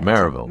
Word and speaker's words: Maryville: Home is in Maryville, Tennessee, and you got to say it like that. – Maryville. – Maryville. – Okay. Maryville. Just swallow as much of it as you can.